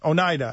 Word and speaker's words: Oneida: I spelt it wrong, pronounced it wrong. Oneida, Indian Nation. And we've got Oneida. I got it. Oneida.